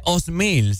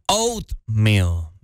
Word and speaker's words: oatmeal: Eh, no se dice osmil, se dice oatmeal.